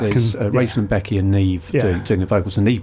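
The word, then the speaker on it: Niamh: Aided by Adrian, and I think it's pronounced Neve, isn't it? – Yes. – Because it's spelt something like Neopa. – Yeah. – But it's Neve, I think.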